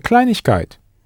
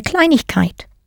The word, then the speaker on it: Kleinigkeit: Eine Kleinigkeit. – Eine Kleinigkeit. – Note the letters IG pronounced ich that sneaked in there between klein and kite the same IG slips into a something sweet.